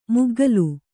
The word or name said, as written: ಮುಗ್ಗಲು